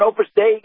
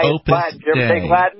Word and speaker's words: Opus Dei: You ever know an Opus Dei guy? – Overstay? – Spell it O-P-U-S, Opus Dei, D-E-I, Opus Dei. – Oh, Opus Dei.